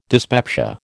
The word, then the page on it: dyspepsia: American English